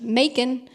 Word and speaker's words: Macon: I recorded the audiobook, and the people at Penguin Random House wrote back to me on a couple things and said, hey, you mispronounced Macon. And I was, it's supposed to sound like bacon.